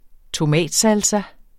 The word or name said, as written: tomatsalsa